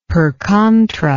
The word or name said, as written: per contra